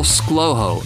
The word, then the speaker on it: ScLoHo: Just Google ScLoHo. It's spelled S-C-L-O-H-O, and you'll probably find more of me.